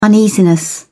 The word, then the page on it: uneasiness: British English